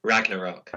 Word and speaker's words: Ragnarok: Ragnarok.